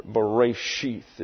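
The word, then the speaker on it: Bereshith (בְּרֵאשִׁית): Bereshith is the way it's pronounced.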